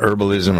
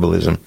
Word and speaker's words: herbalism: The study of some people who not, some people pronounce the H, some people don't, herbalism or herbalism.